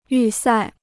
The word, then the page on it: 预赛: yù sài